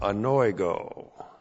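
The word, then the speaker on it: Anoigo (ἀνοίγω): And then we have anoigo. A-N-O-I-G, long O. Anoigo.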